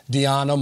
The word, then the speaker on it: Diana: And you let the world know that your name is pronounced Diana, much like Rihanna.